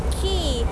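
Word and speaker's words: Clarke Quay: คีย์สะกดหน้าตาแปลกๆนิดนึงนะ QUAY แต่คำนี้อ่านว่าคีย์เหมือนคีย์กุญแจแล้วค่ะ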